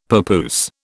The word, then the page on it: papoose: British English